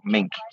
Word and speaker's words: Mink: It's just like the animal, Mink. So Mink.